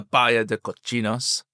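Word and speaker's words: Bahía de Cochinos: So the new landing site, the Bahia de Cochinos, is that how you pronounce it, Gordon? How was that? – Very good. – Is that Spanish? Is that good? – Excellent.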